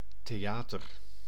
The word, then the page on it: theater: /teˈjaːtər/